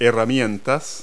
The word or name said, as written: tools